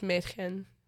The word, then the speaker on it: Mädchen: From Twin Peaks, Madchen Amick. I always say her name wrong. I'm so sorry. That was absolutely... – Her name freaking Mädchen? Freaking girl in German? Mädchen? – Yeah, she... Yeah, it's Mädchen.